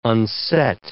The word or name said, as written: unset